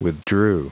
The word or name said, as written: withdrew